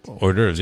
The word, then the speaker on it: hors d'oeuvres: So many hors d'oeuvres. – Oh. – I think that's how you say that. – Hors d'oeuvres, yeah. – Yeah. – Very well pronounced.